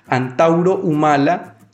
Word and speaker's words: Antauro Humala: A su vez también tiene vínculos con, con el señor, voy a pronunciar bien el nombre, Antauro Humala, que es un ex militar golpista y hace parte del partido ultranacionalista Unión por el Perú.